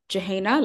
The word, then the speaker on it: Jouhayna: Jouhayna, like even adults struggle with the pronunciation.